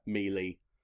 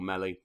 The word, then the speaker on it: melee: So combat has shooting, brawl, uh, melee, or Melly, depends on how you say it.